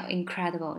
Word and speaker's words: incredible: 这个叫 incredible，就不敢相信啊，unbelievable，incredible，incredible，这个发音就是 incredible，它不是 incredible，它是 incredible，有点像 vegetable 那个 table，还记得我那个 schwa 吗？那个呃呃呃呃，incredible，incredible，incredible，它的重音在 cry 上面。